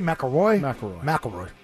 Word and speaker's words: McIlroy: McIlroy? – McIlroy.